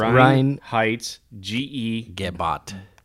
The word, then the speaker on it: Reinheitsgebot: Reinheitsgebot.